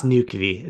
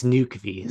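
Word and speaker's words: Nökkvi: The late arrival in, I'm going to butcher his name, but Noki Thorsson, from beautiful Iceland. – Huh? It's Nukvi. It's Nukvi. So like I warned everyone that I would butcher it.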